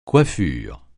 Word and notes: coiffure: francais

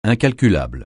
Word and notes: incalculable: francais

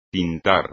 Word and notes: tintar: espagnol

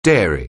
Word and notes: dairy: anglais

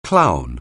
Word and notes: clown: anglais

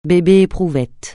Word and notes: bébé-éprouvette: francais

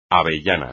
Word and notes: avellana: espagnol

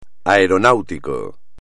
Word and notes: aeronáutico: espagnol

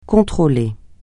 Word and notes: contrôler: francais

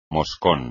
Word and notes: moscón: espagnol

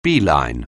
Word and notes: beeline: anglais

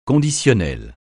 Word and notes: conditionnel: francais

